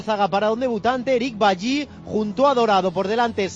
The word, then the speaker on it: Bailly: Bailly, me han dicho que se pronuncia Bailly, de Costa de Marfil.